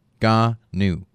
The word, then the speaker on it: Ngannou: How do you say it? – Ngannou. – Ngannou? – Ngannou.